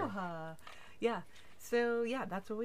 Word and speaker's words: Aloha: Aloha. It's spelled Aloha. A-L-O-H-A. A, yeah. but they call it, but it's pronounced Aloha.